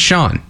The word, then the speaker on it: Sean: But my name is Sean. I spell it S-E-A-N, which is, if it's not the most common, it's the second most common way to spell that name.